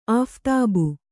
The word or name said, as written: ಆಫ್ತಾಬು